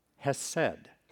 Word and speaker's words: hesed: It would be pronounced hesed, and it has to do with God's faithful, loyal love.